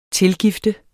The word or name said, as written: tilgift